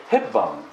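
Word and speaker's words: Hepburn: ヘップバーンと読まないでヘッバーンと読むんです。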